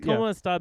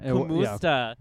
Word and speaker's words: kumusta: Did you call Gerald and be like, hey, Gerald, is it Kamusta? And he's like, it's Kamusta, bitch.